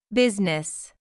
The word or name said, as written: business